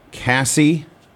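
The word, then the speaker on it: Kasai: Cassie, spelled with a K, K-A-S-A-I.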